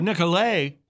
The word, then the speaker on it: Nicolet: Well, in 1634, French explorer Jean Nicolet, or Nicolet, with the French pronunciation.